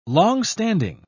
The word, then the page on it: longstanding: British English